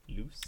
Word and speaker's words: Luce: L-E-L-U-C-E? How are we pronouncing that? Luce? Luce? L-U-C-E. – What language are we talking? – Loose.